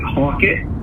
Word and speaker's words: Halkett: I pronounce it Halkett. It's actually yes. – local. The locals pronounce it Hawkett.